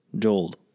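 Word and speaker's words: Jodl: 1946, 10 Nazi leaders are hanged as war criminals after Nuremberg war trials, including Wilhelm Keitel, Joachim von Ribbentrop, and then Alfred Jold.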